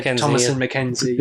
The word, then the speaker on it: Thomasin McKenzie: Is that the right pronunciation? Thomasin McKenzie? – Yeah, I think that's it. Yeah. – Thomasin McKenzie.